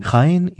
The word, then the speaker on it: Jaén: Jaén, I'm sorry, what is Jaén? – Jaén is actually nearby Córdoba and Granada.